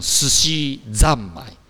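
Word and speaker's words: すしざんまい: 可是呢，它在寿司后面要音变，所以要念成寿司ざんまい。三要变成ざ，呃，本来是三要变成ざ。哦，寿司ざんまい。来念一次，跟老师又念一次。 – 寿司ざんまい。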